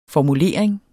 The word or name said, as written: formulering